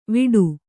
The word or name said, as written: ವಿಡು